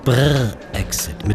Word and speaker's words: Brrr Exit: Brrr, Exit. Mit Betonung auf Brrr.